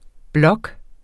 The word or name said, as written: blok